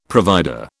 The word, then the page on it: provider: British English